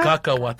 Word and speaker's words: Cacahuate: How do you say it again? Huaca? – Cacahuate.